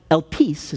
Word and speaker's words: Elpis: Interesting, this word hope that Paul uses, it's a Greek word called, you pronounce it, Elpis is how you pronounce it.